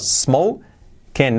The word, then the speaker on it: smoking: Fijaos, smoking, no smoking, no, smoking, smoking. La I como una E, letra clave, y la G casi no se pronuncia, smoking.